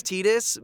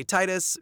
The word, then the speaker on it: Tidus: I know it's Tidus, but I say Titus because he's from the water.